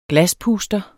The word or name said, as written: glaspuster